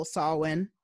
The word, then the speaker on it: Samhain: So I have an expensive gin to drink for this beautiful Samhain.